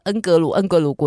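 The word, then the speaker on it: Ngorongoro: 接下来呢，他要教大家一段特殊的恩格鲁恩格鲁国家公园的发音。 – 首先讲的是比较正统的英文版的发音呢，叫做恩格鲁恩格鲁恩格鲁恩格鲁。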